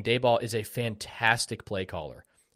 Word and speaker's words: Daboll: I will say, as a Cowboys fan, Dabble, how do you pronounce that? – Brian Daboll. – Daboll.